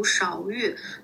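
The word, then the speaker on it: 杓鹬: 对，因为之前就是嗯，好多字我确实也不认识，还有时候还闹过笑话，比如说像那个红玉里面有一种叫勺玉的，它那个勺就是一个木字旁加一个吃饭的那个勺子的勺，但是其实如果它是多音字，它还读标，但是大家都嗯默认它就是读勺玉，然后有的时候我刚开始的时候不知道，还总是标玉，然后也是就有好多字甚至都。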